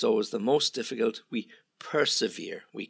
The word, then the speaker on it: persevere: Now, this one is, just be careful of the pronunciation, to persevere. Yeah? Persevere. When something is severe, it's difficult.